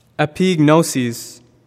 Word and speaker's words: epignosis: It is pronounced epignosis.